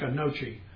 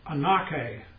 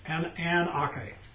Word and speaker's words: anoche: it is spelled A-N-O-C-H-E. It looks like a nochi, but it's anoke, anoke.